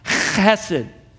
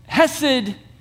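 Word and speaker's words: chesed (חסד): It's actually a Hebrew word that's pronounced chesed. Could we get some Kleenexes down here for Ashley? Sorry about that, Ash. Hesed, that's how I'll pronounce it from now on.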